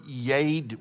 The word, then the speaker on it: yad: It's the Hebrew word yad, Y-A-D, long A.